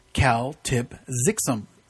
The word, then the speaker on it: Kltpzyxm: If he were to spell his name backwards, it would be K-L-T-P-Z-Y-X-M, which is pronounced Cal-Tip-Zixum.